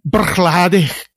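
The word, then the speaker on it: Bruichladdich: Så hon vet banne mig hur man ska uttala det här destilleriet. Ah, hon säger, säker. och det här är ingen överdrift, hon säger Brookleddy.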